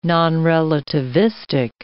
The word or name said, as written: nonrelativistic